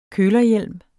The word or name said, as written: kølerhjelm